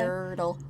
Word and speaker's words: Yurtle: Yurtle. Like the Dr. Seuss book. Yurtle the Turtle.